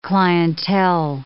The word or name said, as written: clientele